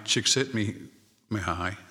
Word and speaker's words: Csikszentmihalyi: Csikszentmihalyi. Let's try that again. Mihai Csikszentmihalyi. It's a Czech name and hard to pronounce, but the book is called Flow.